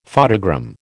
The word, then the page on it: photogram: American English